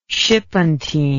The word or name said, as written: shipentine